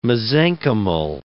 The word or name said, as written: mesenchymal